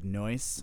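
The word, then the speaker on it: nóis: They're saying the word noise, so it's actually N-O-I-S. Noise in Portuguese means us, we, together. So it's noise. It sounds like the word English word noise, like noise, loud,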